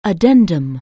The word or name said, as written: addendum